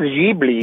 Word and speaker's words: Ghibli: I think it's Ghibli.